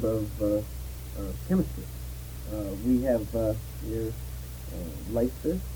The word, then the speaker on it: Leicester: It's pronounced Leister.